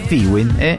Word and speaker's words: FIWIN: FIWIN se escribe así, FIWIND, FIWIN, y se pronuncia FIWIN.